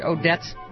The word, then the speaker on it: Odets: It tone somehow reminded me of the stirring speech written by the American playwright Clifford Odets.